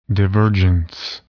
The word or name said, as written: divergence